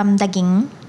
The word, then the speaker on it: daging: How do you say beef? – Beef, daging.